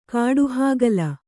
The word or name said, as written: ಕಾಡಹಾಗಲ